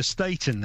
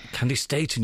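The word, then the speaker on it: Staton: I think they call her Staten now, don't they? – Candy Staten, you're right. I heard a documentary and that's how they pronounces her name.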